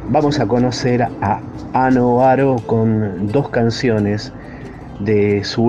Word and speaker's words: Oaro: Se escribe A-N-N, Anne Oaro, O-A-R-O, Anne Oaro, desde Isla de Reunión, en este planeta folk.